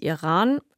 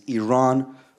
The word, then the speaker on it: Iran: First of all, you say you support the Iranian people, but you're pronouncing our country's name wrong. Our country is named Iran, not Iran.